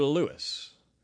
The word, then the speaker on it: Lewes: It's spelled L-E-W-E-S. How do you pronounce that word? L-E-W-E-S. E-S. Is it Lewes or Lewis? It depends on who you ask.